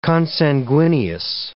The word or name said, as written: consanguineous